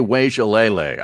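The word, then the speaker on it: Uiagalelei: DJ Wajalele, I believe, is how you pronounce it.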